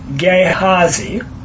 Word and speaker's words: Gehazi: Gehazi. – Gehazi. Gehazi. Like G-A-Y-H-A-Z-E-E.